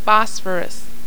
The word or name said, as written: phosphorus